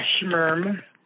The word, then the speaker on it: Schmerm: Yeah, Schmerm. – Schmerm. – It's basically what people kind of do when they're not sure what gender to refer to me as. – Sure. – It's just like a schmerm.